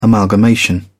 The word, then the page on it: amalgamation: British English